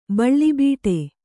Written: ಬೀಳುಬಿಡು

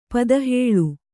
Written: ಪದಹೇಳು

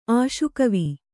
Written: ಆಶುಕವಿ

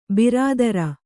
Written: ಬಿರಾದರ

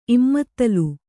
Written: ಇಮ್ಮತ್ತಲು

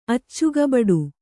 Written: ಅಚ್ಚುಗಬಡು

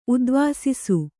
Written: ಉದ್ವಾಸಿಸು

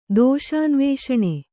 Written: ದೋಷಾನ್ವೇಷಣ

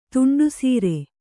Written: ತುಂಡುಸೀರೆ

